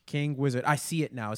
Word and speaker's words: KNGWZR_D: I think it's King Wizard. – There you go. – Yep, King Wizard.